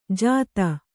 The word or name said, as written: ಜಾದೂ